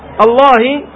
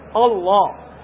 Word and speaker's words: الله: لأن الهمزة جاورت حرف مفخم الذي هي لام لفظ الجلالة، الله، الله خطأ، الله، الله، فتنطق الهمزة مستفالة ثم اللام مفخمة ثم الهاء مستفالة.